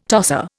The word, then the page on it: tosser: Indian